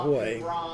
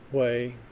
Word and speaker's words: Hue: Then we drove around Hue, and it's spelled H-U-E, and I still pronounce it W-A-Y.